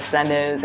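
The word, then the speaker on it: flâneuse: the flaneuse, and what that means and what I'm doing with it, and, you know, we'll sort of take it from there.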